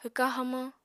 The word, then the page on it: hwka hamañ: apatani